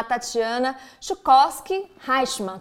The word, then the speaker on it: Tatiana Schuchovsky Reichmann: Tenho o prazer de chamar a Tatiana Chukowski Reichmann.